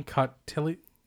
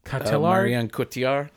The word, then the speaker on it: Cotillard: It is starring, and I'm going to need help with this, Adam Driver and Marion Cotillard? – Marion Cotillard. – Is that how you say it? That's what I thought. – Cotillard. – Cotillard? – It's French.